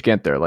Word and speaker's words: Ginther: Cool name, Gage Ginther. Like you can only be like an offensive tackle or like just a closer in baseball with Gage Ginther.